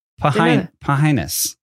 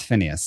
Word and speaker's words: Phinnaeus: It is P-H-I-N-N-A-E-U-S. – Whoa, that's wild. – Pahinus. – That sounds like... – Pahinus, pronounced Phineas.